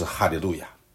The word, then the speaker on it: 哈利路亚: 所以我们说这最后的 5首诗是哈利路亚诗歌。在 146章的 1节说，你们要赞美耶和华。前面也出现过，我们也分享过，赞美耶和华这个在希伯来文里面，这句话的发音，这个词的发音就是哈利路亚。